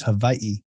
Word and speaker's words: Hawaii: And if you want to really get technical, the W's here are actually pronounced as a V. So it's Hawaii.